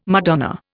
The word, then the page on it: Madonna: Indian